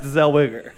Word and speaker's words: Zellweger: Who, I believe it's pronounced Zellweger.